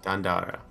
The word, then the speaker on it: Dandara: And I'm pretty sure I'm pronouncing that correctly. – Yes, exactly. – It's kind of like, it's Dandara? – Yes. – Dandara. – Okay. Ooh, and then you have like the little R.